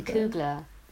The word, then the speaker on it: Coogler: Googler, wow, get his name right. Ryan Coogler.